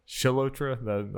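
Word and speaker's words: Chalotra: Anya Kalotra? Anya Kalotra. Probably butchering that. – Shalotra? – That sounds more right.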